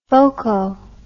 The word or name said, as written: foco